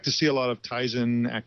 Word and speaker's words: Tizen: There's also these alternative operating systems like Tizen, if I'm saying that right. – Yeah, Tizen.